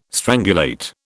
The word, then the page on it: strangulate: British English